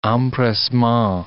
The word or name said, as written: empressement